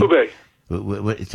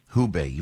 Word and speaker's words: Hubei: What's the Wabe? Is that the way you pronounce it, Gordon? The Wabe province? Is that where all this is happening? – Hubei. – Tell me again? – Hubei. – Hubei, right.